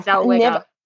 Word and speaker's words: Zellweger: Is it Zellweger? – Zellweger.